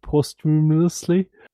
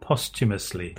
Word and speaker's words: posthumously: We had Windigo who's saying, "Phew, I kept expecting a message on the end of the episode saying that it was uploaded posthumously." Can I get a spell check there, please? – Most people pronounce that posthumously.